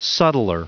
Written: subtler

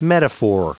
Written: metaphor

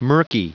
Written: murky

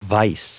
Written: vice

